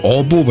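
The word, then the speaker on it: Åbo: Das heißt eigentlich Orbo, weil ich bin ja Schwedisch spreche.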